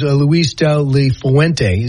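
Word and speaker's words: Luis de la Fuente: He said as soon as he was done playing, he went to Spain to a coaching academy, and his coach was Luis Dele Fuente.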